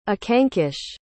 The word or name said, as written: akanksh